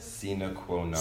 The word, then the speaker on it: sine qua non: Something in Latin, sine qua non. – How do you pronounce it? – Sine qua non.